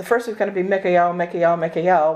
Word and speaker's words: Mikael: It's a different pronunciation of it, um, but it's Mikael, Mikael, Mikael. he who is like unto God. The El part is, is an ancient um, Hebrew name for God.